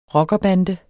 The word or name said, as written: rockerbande